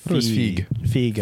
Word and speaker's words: Feige: Is it Feige or Feige? – Feige. – I think. – Yeah, it rhymes with Teague.